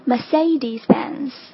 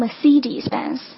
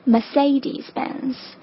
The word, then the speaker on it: Mercedes-Benz: 车的牌子似乎比时尚品牌更让人纠结，我们不妨先从简单的开始。第一个大家最最熟悉的奔驰，正确发音是 Mercedes-Benz。 OK，第二音节的发音是 say，就是说话这个 say 的发音。有很多人会发成 Mercedes-Benz，这个是错误的，正确发音 Mercedes-Benz。